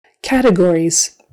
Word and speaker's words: categories: Categories, so syllable stress on the first syllable, categories. Not categories, categories.